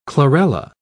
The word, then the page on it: chlorella: British English